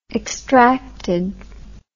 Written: extracted

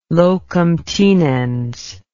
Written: locum tenens

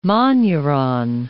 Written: monuron

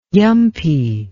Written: yumpy

